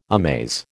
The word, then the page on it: amaze: American English